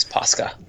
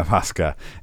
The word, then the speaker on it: Pascha: Pascha. And I'm actually sure if I'm pronouncing it right, but I've more read it. – It's Pascha. – Yeah, Pascha.